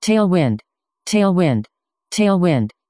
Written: tailwind

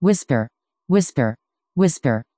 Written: whisper

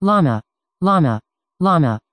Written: llama

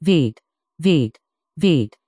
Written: vite

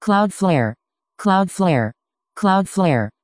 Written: cloudflare

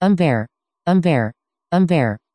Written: ember

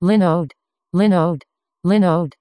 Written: Linode

